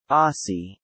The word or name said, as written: Aussie